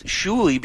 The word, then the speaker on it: Shuli: I went with Shuli because Shauli, when it's spelled on a piece of paper, people have no clue where to go with that.